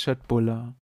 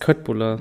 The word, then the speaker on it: köttbullar: Nein, das heißt Schöttbuller. – Das heißt Köttbuller. Nein, das wird nur so geschrieben, aber das K wird wie SCH ausgesprochen.